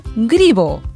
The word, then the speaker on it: Greboo: La mascota se llama Gribo. – Gribo. – Gribo. Pero se escribe en letras romanas G-R-E-B-O-O. Grebo, ¿no? Pero se lee Gribo.